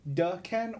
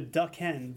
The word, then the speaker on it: Daken: Now on his penultimate issue, how do you pronounce the name Daken? And he says Ducken or Daykin. It's Wrong on both counts. – Yeah. So how about that? It's closer to Duck Hen, but it's it's definitely Dockin, like like the band.